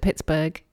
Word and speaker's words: Pittsburgh: Readers, it's very hard for me to look at the word Pittsburgh and pronounce it Pittsburgh because, my brain wants to read it like Edinburgh.